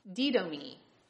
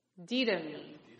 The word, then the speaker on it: didomi: The word is didomi. Didomi. Say it with me. Didomi. All right. It's spelled D-I-D-O-M-I. Didomi. Any guesses what language this word might come to us from?